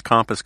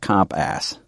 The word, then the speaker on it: compass: You pronounce it sort of like comp ass. Instead of compass.